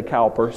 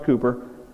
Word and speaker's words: Cowper: William Cowper, some say Cowper, spelled Cowper, pronounced Cooper, was a man whose soul was afflicted throughout his life with debilitating depression.